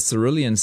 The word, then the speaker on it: Cerulean: So I've been giving it a hard C, so apologies if anyone that was annoyed by that. The House in the Cerulean Sea by T.J. Clune. That's spelled K-L-U-N-E.